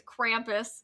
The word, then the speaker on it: Krampus: Well, the American way to say it is Krampus. Because it does originate in Austria and Germany, everything I looked at pronounced it more like Krampus.